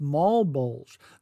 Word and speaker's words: Malbolge: and that's spelled M-A-L-B-O-L-G-E, and I believe it's pronounced Mall Bulge.